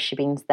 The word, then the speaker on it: shebeen: And then you're experiencing watching them perform this druids ball in this shebeen. That's how you say it, isn't it? – Shebeen.